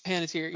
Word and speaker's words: Panettiere: Hayden Panettiere, or however you say her name? – Panettiere.